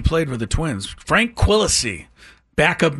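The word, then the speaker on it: Quilici: I don't even know how to say this. Q-U-I. – Oh, I'll get you. – Quisano? – L-I-C-I. – Oh, Quillacy. – Quillacy. – Yeah, I'm going Frank Quillacy.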